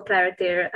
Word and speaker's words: Clariter: Am I saying that correctly? That company's name, Clariter. – Yeah, Clariter. – Okay. Yeah. What is the experience like working for Clariter and what have you learned so far during your time there? – I don't know where to start.